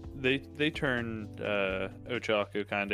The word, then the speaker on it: Ochako: I think they've used Okocha. Can't say her name right. – Ochako.